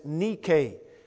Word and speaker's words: Nike: There are varying pronunciations of that word. And yet the Greek pronunciation is Nike.